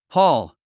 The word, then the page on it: haul: hɔ́ːl; ホール